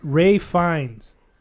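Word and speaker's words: Ralph Fiennes: So it's spelled out R-A-L-P-H, but it's pronounced Ray Fiennes. It's F-I-E-N-N-E-S.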